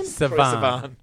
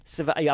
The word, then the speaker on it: Sivan: How do you say his name? – Sivan. – Sivan.